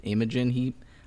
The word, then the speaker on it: Imogen Heap: Imogen Heap.